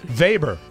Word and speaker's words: Weber: You could use the German pronunciation, Weber.